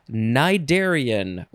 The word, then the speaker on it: cnidarian: All right, our last word is cnidarian. Why do we have a word that starts with N? It is spelled C-N-I-D-A-R-I-A-N, cnidarian.